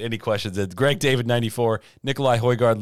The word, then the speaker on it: Nikolai Højgaard: Lastly, Nikolai Højgaard, which I learned the pronunciation actually is, wins on the European Tour.